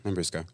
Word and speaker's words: Lambrusco: That's not really a cocktail, but I love Lambrusco. I hope I'm saying that right. – You are, Lambrusco.